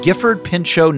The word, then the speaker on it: Gifford Pinchot: The Gifford Pinchot National Forest is where your character is hiking in The Dark Divide, correct? Am I pronouncing it correctly? – Yes, that is correct.